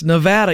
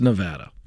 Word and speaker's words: Nevada: I got to tell you, I've said it wrong enough to finally get it right. It's Nevada. Even though I want to say Nevada, it's Nevada.